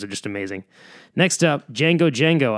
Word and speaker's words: Django Django: They're spelled like, you know, Django Unchained, but just Django Django. You know, like the Quentin Tarantino movie, but D-J-A-N-G-O, and then just twice, Django Django.